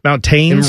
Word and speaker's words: mountains: How do you say mountains? Do you say mountains? – Mountains. – And really pronounce that T? – Mountains.